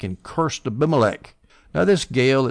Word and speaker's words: Gaal: Now, Gaal, that's G-A-A-L, if Baal can be pronounced Baal or Baal, I guess Gaal can be pronounced Gaal or Gaul, but I'm going to pronounce it Gaal.